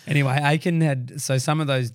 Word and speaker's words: Achan: Well, we say Achan.